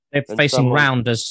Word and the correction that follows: Randers: sorry, Randers in the, the Europa.